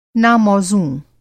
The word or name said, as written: ना-मौज़ूं